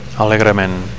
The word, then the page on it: alegrement: Catalan